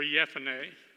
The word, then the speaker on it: Jephunneh: Caleb, the son of Jephunneh, or Jephunneh. J's aren't really pronounced. If you go into the Bible and you look at any of the resources, the J's are not pronounced J. They're pronounced Y.